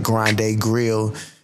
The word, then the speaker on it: Grande Grille: And far as meal prep goes, you can also find me on IG at Grande Grille. And it's spelled that is G R A N D E G R I L L E. That's Grande Grille, pronounced Grande Grill.